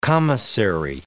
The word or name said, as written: commissary